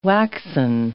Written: waxen

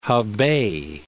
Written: Hebei

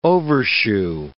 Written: overshoe

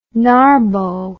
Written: narbo

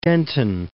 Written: Denton

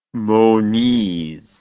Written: Moniz

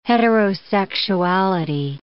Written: heterosexuality

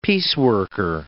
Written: pieceworker

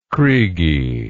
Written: kriegie

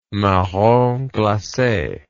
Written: marrons glaces

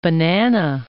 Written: Banana River

